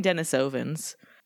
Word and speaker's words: Denisovans: Anyway, we're talking about the Denisovans, which I choose to pronounce that way because I looked it up and it turns out. – What, did you ask one? – No, I asked the phonetic, because it's named after Denisova Cave, which is where they were found. And that's apparently how you pronounce it. I've been saying Denisovans.